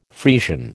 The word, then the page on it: Friesian: American English